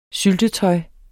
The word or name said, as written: syltetøj